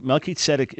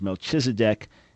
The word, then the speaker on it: Melchizedek: Melchizedek is the way to pronounce his name in Hebrew. In English, he's often referred to as Melchizedek, and pick which you like. If you say it the right way, Melchizedek, people will think you're pronouncing it incorrectly. Unless you're me, then they'll believe I'm pronouncing it correctly.